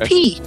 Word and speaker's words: pi: When I was in college, I studied ancient Greek, and people are not aware that the letter P-I, which is 3.1415, etc., is pronounced P. It's not pronounced pi, it's pronounced P.